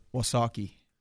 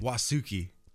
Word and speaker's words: Wausaukee: Okay, the first one. WAUSAUKEE. John K. – Wasaki. – Wasuki. What? Come on. – John K is correct.